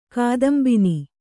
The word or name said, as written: ಕಾದಂಬಿನಿ